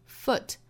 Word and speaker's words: foot: 而 P-U-L-L 应该是 pull, pull. Just keep putting one foot. In front of the other, 后面的这个 foot 也很巧啊，它也是 o 的发音，所以我们读的时候应该是 foot, put.